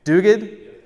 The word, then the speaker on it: Duguid: As I love, listen to what Ian, Ian Duguid, how do you say it? Duguid? There you go.